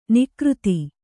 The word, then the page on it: ನಿಕೃತ: Kannada